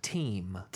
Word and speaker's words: Thiem: Nadal survives five-hour match versus Thiem in US Open. It's actually pronounced Dominic Thiem.